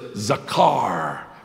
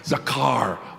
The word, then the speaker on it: zakar: The word is zakar, and you can use the back of your throat for the part, zakar.